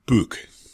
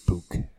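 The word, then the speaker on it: BYK: And you also may want to consult with the people from BIC, which in Germany it's actually pronounced BUK. – BUK.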